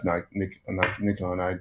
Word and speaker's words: Nikon: That's when I say left Nikon. I owned a set of Nikon. I gave them to Nikon is the Japanese pronunciation as opposed to the way we've been saying it wrong in the States now.